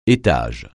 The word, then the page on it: étage: français